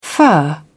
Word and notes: fur: British English

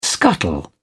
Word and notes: scuttle: British English

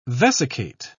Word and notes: vesicate: British English

